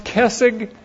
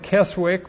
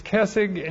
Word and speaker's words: Keswick: That's pronounced Kessig, but it looks like Keswick, but it's Kessig, and this is also called the higher life.